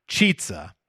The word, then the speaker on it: Chizza: And they're calling it the Chizza, pronounced Chizza.